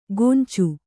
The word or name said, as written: ಗೊಂಚು